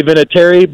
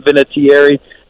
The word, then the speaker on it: Vinatieri: So, yeah, it's we say Vinatieri, but I've heard a lot of people pronounce it with more enunciation. So if you want to call me Adam Vinatieri, knock yourself out.